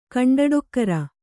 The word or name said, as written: ಕಂಡಡೊಕ್ಕರ